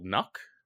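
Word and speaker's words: Nøkk: So the two operators, the one that we've received the information on so far is called Nuck. – Well done on that pronunciation, by the way.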